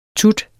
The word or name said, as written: tut